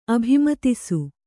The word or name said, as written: ಅಭಿಮತಿಸು